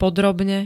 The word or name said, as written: podrobne